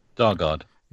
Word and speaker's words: Dargaud: Dargaud.